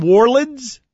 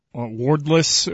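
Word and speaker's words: Worilds: And am I pronouncing this name right? The Pittsburgh Steeler who became a Jehovah's Witness. Is it Jason Wardlitz? – Wardless.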